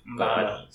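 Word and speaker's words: Mboma: But what I just said is like sort of the opposite of the Christina Obama. I'm saying this as a joke, which is bad because I'm not sure how to pronounce it. Mbama. – I believe. Mbama. – Sorry. Christina Mbama.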